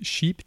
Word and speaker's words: sheep: Eh, men i det här fallet ska vi då prata om hans bok eh, Do Androids Dream of Electric Cheap?” – Prosit. Eh, tack. Det är sheep, cheap, det är svårt att uttala. Alltså inte som billigt, utan som får.